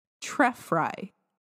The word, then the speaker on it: Trefry: This episode was written by Kate Trefry. I think that Tref- sounds right. Trefry it's T R E F R Y.